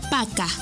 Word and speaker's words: пока: Para finalizar, la palabra adiós se dice пока.